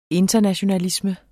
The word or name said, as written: internationalisme